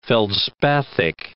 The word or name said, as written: feldspathic